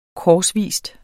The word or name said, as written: korsvis